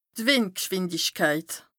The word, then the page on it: d' Wìndgschwìndichkeit: Bas-Rhin; Strasbourg